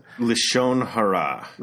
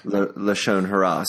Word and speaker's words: lashon hara: Is it Shalom Hara? Is that what you said? – Lashon Hara. – Lashon Hara.